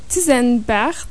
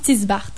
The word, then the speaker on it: Tisenbart: Il y a aussi Bénédicte de Tisenbart. Est-ce comme ça qu'on le dit? – Elle le prononce Tisbart.